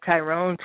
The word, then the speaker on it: Tyrone: You can find me on Facebook at Tyrone, T-Y-R-O-N-E, like the song.